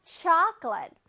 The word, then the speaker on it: chocolate: Chocolate. Chocolate. 앞에 C-H-O-C의 강세가 들어가서 chocolate. 그냥 초콜릿이 아니라 chocolate. 오케이?